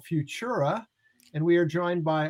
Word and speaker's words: FVTURA: So it's, you call it fyoo-chura, but it's like spelled a little funky.